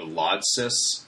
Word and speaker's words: Lodsys: Apple to Lodsys, is that how you guys pronounce that? – That's how I would say it.